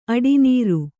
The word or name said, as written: ಅಡಿನೀರು